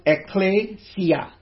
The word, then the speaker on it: ekklesia: Now, the Greek word, as we know, if you're a student of the Bible, you would know, it's pronounced ekklesia. Ekklesia.